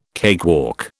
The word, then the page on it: cakewalk: British English